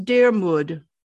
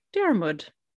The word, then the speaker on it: Diarmuid: So his first name is spelled D I A R M U I D, which is Dermud. – Dermud.